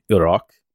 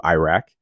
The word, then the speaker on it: Iraq: Like, you just educated me on the correct way to say Iraq as opposed to Iraq, and I appreciate that.